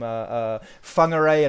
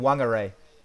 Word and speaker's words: Whangarei: Oh, also what I love with the WH, just to make it even more contentious, apparently if you go to the north of the North Island, they do pronounce it as a W instead of an F. And so you can have this argument all over again. The difference between, what's that place name? Whangarei and Whangarei.